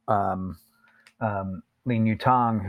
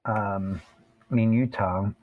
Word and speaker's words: Lin Yutang: Second tone for his family name, Lin. – Yep. – Yu is the third tone.